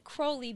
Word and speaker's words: Crowley: Well, he said Crowley because it rhymes with holy.